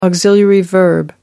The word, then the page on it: auxiliary verb: British English